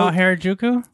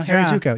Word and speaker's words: Harajuku: Or, I got it wrong. Hakujari. Anyway. They go Harajuku? – Yeah, there you go. Harajuku, yeah. And they get up with the colorful outfits. – I'm saying it wrong, but... – But you got it right. You got it faster than I did.